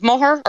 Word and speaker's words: Moher: So generally it's the Cliffs of Moher, or others in the county would also call it the Cliffs of Moher, but we are pronouncing the H in the word Moher. – But this company, Preply, say it's the Cliffs of Moher. – So how we pronounce it in Clare is the Cliffs of Moher. So I suppose the people local to the area pronounce the words the cliffs with the H in it.